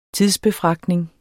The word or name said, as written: tidsbefragtning